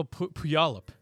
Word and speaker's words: Puyallup: Oh, what's Polyup? – Oh, Puyallup. – Yeah, Puyallup. Polyup.